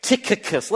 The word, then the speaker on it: Tychicus: Number one, the very first one he mentions here is a guy named Tychicus. You say, wait a minute, C-H. Well, the C-H is pronounced with a K. Tychicus.